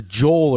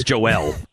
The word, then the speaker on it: Jor-El: You pronounced, it was a Joel or something, and you called, it was Joel, and you called him Jor-El. – Superman's father is Jor-El. It was not Joel, and I said Jor-El.